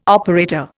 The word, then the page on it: operator: Indian